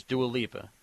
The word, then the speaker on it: Dua Lipa: And yeah, I always said her name wrong for a long time. It's Dua Lipa.